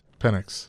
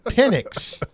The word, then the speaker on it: Penix: Penix. – All right. I about said something else. Not with the S, but I pronounce it differently. Michael Penix. Penix. Like Publix. Penix. Publix.